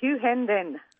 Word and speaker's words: Hughenden: And it's Hughenden. It's spelt with an E-N in the middle, isn't it? – Yeah, it's Hughenden.